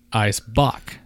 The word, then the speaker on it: Eisbock: Pronounced ice, as in like ice cube or iced tea. – Or ice, ice baby. – Ice bock. – Not ice? – No, not ice. It's actually spelled E-I-S, for those of you who are not reading this.